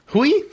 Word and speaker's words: Hui: Hui? Sorry. – Yeah. It's like we, but with an H. Hui.